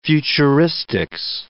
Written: futuristics

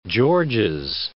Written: Georges Bank

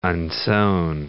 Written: unsewn

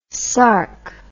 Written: sarc

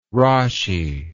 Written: Rashi

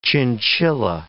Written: chinchilla

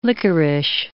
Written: licorice

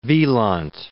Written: Wieland

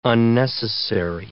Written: unnecessary

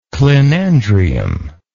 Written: clinandrium

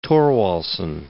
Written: Thorwaldsen

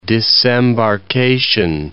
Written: disembarkation

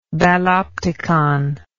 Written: balopticon

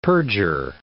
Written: perjure

